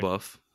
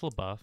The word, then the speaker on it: LaBeouf: Yeah, I pronounce it LaBeouf. Yeah, it's LaBeouf.